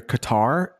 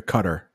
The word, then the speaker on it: Qatar: Sometimes you'll hear Qatar, and then sometimes you'll hear Qatar. Yeah, both pronunciations.